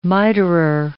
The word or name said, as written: miterer